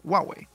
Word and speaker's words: Huawei: No es Huawei ni Huawei, no, se dice Huawei. Ah, Huawei. Porque son dos sílabas chinas, que es Huawei. Pero entonces la pronunciación es Huawei.